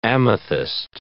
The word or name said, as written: amethyst